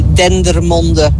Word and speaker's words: Dendermonde: The name of the town in Belgium that you were trying to pronounce is actually Dendermonde.